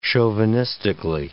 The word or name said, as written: chauvinistically